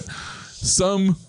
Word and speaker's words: Summon: Aside, it's a silent N? – It's a silent N. – We could do summon.